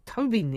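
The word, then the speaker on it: Tóibín: Cullen Tobin, or Tobin even, is the correct pronunciation.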